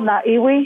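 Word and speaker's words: Ngā Iwi: Naga Iwi. Hey, is that's how you say it? – It's called Na'iwi. It means tribe, and it's Maori.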